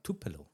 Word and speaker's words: Tupelo: Man muss ja ehrlich sagen, du bist Ehrenbürger von Tupelo. Habe ich das richtig ausgesprochen? – Ja, Tupelo.